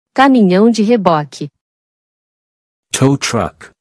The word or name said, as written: Caminhão de reboque